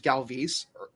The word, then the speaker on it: Galvis: Is that I found out this year his name is pronounced Galvis, and it ruined my life. – Like, oh, he's like a whole – that's like a whole new person I have to learn now. So maybe like, Freddy Galvis will be like a talented ball.